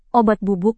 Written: obat bubuk

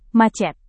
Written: macet

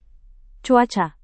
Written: cuaca

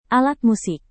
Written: alat musik

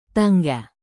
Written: tangga

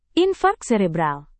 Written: infark serebral

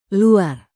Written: luar

